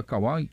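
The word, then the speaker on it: Kwai: TikTok também da Rádio Gaúcha, Kawaii também. É Kawaii a pronúncia certa, né? É Kawaii? Aquela rede social é Kawaii, Kawaii, né? Isso é.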